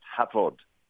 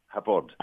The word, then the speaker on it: Hafod: It's spelled H-A-F-O-D, but F in Welsh is pronounced V, so it's pronounced Havod, Havod.